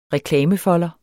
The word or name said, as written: reklamefolder